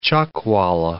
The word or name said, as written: chuckwalla